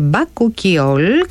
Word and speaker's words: Bakuchiol: Προφέρεται Bakuchiol και τώρα καταλαβαίνω γιατί.